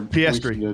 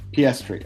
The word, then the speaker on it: Piastri: So, do you know how to say this guy's name, this kid's name? I feel like we're... – Piastri. – Piastri, okay. Yeah, I did Piastri.